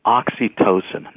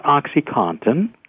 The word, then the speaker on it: oxytocin: But the problem is this drug blocks another chemical of pleasure, a chemical called oxytocin, not OxyContin, oxytocin. – How do you spell that? O-X-Y-T-O-C-I-N? – O-T-O-C-I-N, right. – Okay. – O-X-Y, oxytocin, T-O-C-I-N.